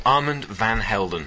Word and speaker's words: Armand van Helden: Go on, you can pronounce it. – Armand van Helden. – Thank you. Not Van Halen. Apparently that was a different band.